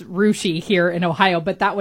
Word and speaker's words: Russia: It's spelled Russia. It's Rushi.